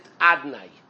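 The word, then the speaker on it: Adonai: Hashem's name, even though it's written Yud-Kei Vav-Kei, and there's certain vowels on the letters of Yud-Kei Vav-Kei, but we pronounce it Adnai.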